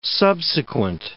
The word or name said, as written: subsequent